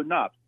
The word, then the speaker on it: Knopp: Yes, Joe Knopp. Good to be on with you tonight. – Yep, all righty. Your name's spelled K-N, though, isn't it? – Yeah, the K is silent this time around.